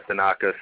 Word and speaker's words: Tanaka: Tanaka.